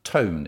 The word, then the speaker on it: Toon: So you've got the, uh, the, the father, Toon is pronounced, I, I discovered in the article, it rhymes with bone, Toon Claes, and his two sons and they are the go-to guys.